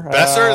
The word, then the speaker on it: Boeser: Any updates on Brock Boeser? – Besser? – That's how you say it? Sorry. – Yeah, that's all right.